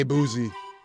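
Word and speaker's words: Boosie: There's not a T, but you pronounce a T? – I've never heard anybody say Boozy. – It's B-O-O-S-I-E.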